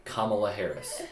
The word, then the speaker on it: Kamala Harris: All right, let me try and say our vice president's name. Kamala Harris. – Harris, yeah. – I did it? Okay. – You got it.